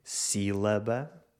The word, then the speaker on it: sílaba: Em certas palavras, a sílaba tónica pode ser a antepenúltima, como em sílaba, ou a última, como em café ou aqui.